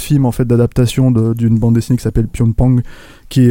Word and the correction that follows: Pyongyang: est, euh, si je prononce bien, je c'est mm. du nom. – Pyongyang, Pyongyang pardon.